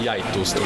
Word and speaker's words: Gjetost: And it's pronounced Gjetost? – Yeah, in Norway we call it Brunost, which is brown cheese, or Gjetost, which is goat cheese.